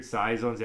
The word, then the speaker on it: saison: Then they have some weird saisons. They have a couple barrel aged options. Like, I try like a barrel aged saison, or how do you pronounce that word? – Saison.